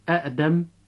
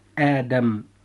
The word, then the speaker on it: آدم: إخوة الإسلام، لاحظتم معنا أن هذا التغيير هو أصلا للتخفيف على نطق هذه الكلمات، فلا نستطيع أن نقول مثلا أأدم أو أأزر أو إئمانا أو الأولى، ولكن نقول آدم.